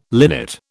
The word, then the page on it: linnet: British English